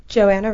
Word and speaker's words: Joanna: Joanna Ray. – Okay, so we don't pronounce the H.